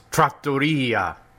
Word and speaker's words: trattoria: It's pronounced trattoria. You put the emphasis on the wrong syllable.